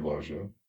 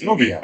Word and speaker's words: Loggia: Is that how you pronounce his name? – Loggia. – Robert Loggia. – Loggia? – Loggia. – I thought it was Loggia. – It is Robert Loggia. – Oh, it's Loggia.